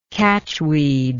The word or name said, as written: catchweed